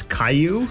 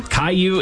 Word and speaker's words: Caillou: Okay, actually, I've never heard his name pronounced that loud, but it's Caillou. – Caillou is correct.